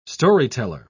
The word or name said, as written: storyteller